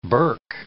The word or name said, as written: berk